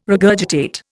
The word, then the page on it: regurgitate: Indian